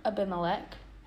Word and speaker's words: Abimelech: And this is a psalm that David wrote when he pretended to be insane before Abimelech. I think that's how you pronounce it.